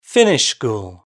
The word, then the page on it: finish school: British English